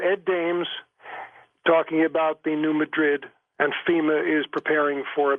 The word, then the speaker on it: New Madrid: I've heard a lot of different pronunciations of that, especially from guys down in that area. But well, I lived there. It was New Madrid.